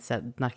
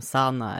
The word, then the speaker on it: Sedna: Och det är ju inuiterna som har henne som havsgudinna och hon kallas även då, eller namnet Sedna kan också ut, uttalas som Sana eller Sidna.